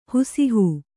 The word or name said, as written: ಹುಸಿಹೂ